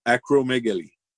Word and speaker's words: acromegaly: Acromegaly.